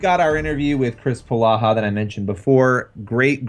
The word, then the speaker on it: Polaha: And then earlier I was like, kept saying Pohala, and you're like, it's Palaha, you idiot.